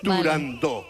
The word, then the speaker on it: Turandot: Para que quede claro, es Turandot.